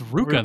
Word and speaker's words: RVCA: I don't know who told me that, but yeah, the RVCA is pronounced Ruka.